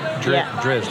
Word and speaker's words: Drizzt: I did manage to read the, how do you even say his name? Dritz books? – Drizzt? – Drizzt. Him.